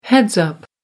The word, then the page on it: heads-up: American English